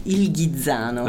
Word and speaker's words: Il Ghizzano: So how do you say that again? – Il Ghizzano. – The Ghizzano, okay. – Yeah. The Ghizzano.